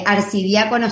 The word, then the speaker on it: Arcidiácono: Eh, arcidiácono está muy bien dicho.